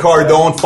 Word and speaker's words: Cardone: Hey, Grant Cardone.